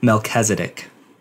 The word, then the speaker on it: Melchizedek: Melchizedek.